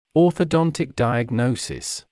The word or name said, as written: orthodontic diagnosis